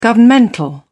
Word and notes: governmental: British English

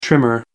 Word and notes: trimmer: American English

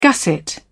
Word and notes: gusset: British English

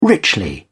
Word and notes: richly: British English

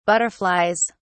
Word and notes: butterflies: British English

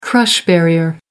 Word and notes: crush barrier: American English

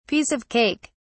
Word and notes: piece of cake: British English

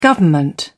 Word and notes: government: British English

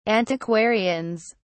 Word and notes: antiquarians: British English

